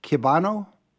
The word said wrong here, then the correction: Klebanow: Klebanow. – Klebanow, thank you.